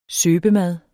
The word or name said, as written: søbemad